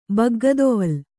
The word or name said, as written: ಬಗ್ಗದೊವಲು